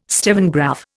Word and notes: stevengraph: Indian

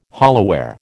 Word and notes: hollowware: American English

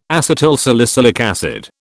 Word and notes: acetylsalicylic acid: British English

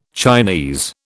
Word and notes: Chinese: British English

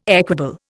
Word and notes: equable: Indian

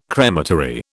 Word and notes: crematory: British English

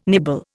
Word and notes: nibble: Indian

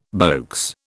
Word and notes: beaux: British English

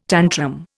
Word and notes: tantrum: Indian

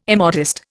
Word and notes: immodest: Indian

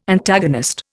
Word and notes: Antagonist: Indian

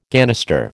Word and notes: ganister: American English

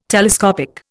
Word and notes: telescopic: Indian

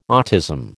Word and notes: autism: American English

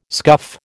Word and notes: scuff: American English

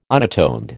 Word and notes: unatoned: American English

